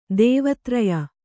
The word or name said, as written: ದೇವತ್ರಯ